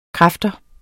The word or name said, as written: kraft